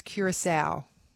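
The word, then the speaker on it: Curaçao: I think it might be Curacao. – That is correct. One thing I learned this World Cup is the pronunciation is Curacao.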